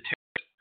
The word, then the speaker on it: Tarot: It's Taro, it's Taru, it's Taro, Taru, Taru, and all that stuff, but I'll say it any damn way I please, and I usually pronounce it Taro. That's how it's spelled, T-A-R-O. Tarot, and it's originally Italian, Tarocchi, and it's Italian, pronounce that final T, it's Tarot.